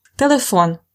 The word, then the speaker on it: Телефон: Телефон is a phone. Телефон. Pay attention, it's not like in English, the stress is at the last syllable.